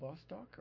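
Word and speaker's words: Vostok (Восток): It's called Vostok or Vostok, pronounced Vostok with a V, but spelled with a B in the Cyrillic Russian alphabet, the B-like symbol.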